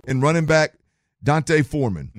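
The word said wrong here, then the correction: D'Onta: Is it Deonte or Dante? How does he pronounce that one? – Deonte. – Deonte. I've heard it two different ways.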